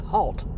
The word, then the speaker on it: Hoult: Nicholas Hoult in this.